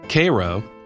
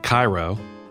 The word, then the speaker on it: Cairo: But like many Georgia towns, Vienna, not Vienna, Cairo, not Cairo, it's pronounced a distinctly southern way, Bremen, in this case.